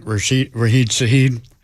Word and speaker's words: Rashid Shaheed: Rashid Shaheed, did I say that right? – Yes, you did.